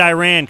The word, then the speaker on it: Iran: I say it Iran because I'm in America.